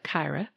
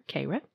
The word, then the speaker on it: Kayra: To use her findings to do good, Dr. Kayra Wagoner, it looks like Kyra, but luckily they have the pronunciation here, Kayra, Wagoner has decided to be both, launching a startup with UNCG alum Phoebe Snyder.